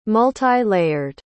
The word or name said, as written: multi-layered